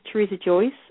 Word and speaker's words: Teresa Joyce: My name is Teresa Joyce, and it's spelled T-E-R-E-S-A-J-O-Y-C-E.